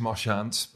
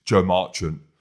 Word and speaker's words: Joe Marchant: Marchant? Yeah, is that the pronunciation? – No, in your French it is Marchant, but in English it's Joe Marchant.